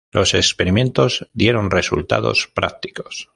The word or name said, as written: experimentos